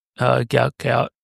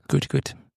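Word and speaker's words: Bol: Gout, not how you say his name, but Good, isn't it? – Good. – Because his dad's like told the media that it's not incorrectly pronounced and they're still pronouncing it Gout.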